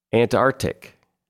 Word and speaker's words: Antarctic: Antarctic. You're supposed to pronounce that hard C in the middle. It's Antarctic.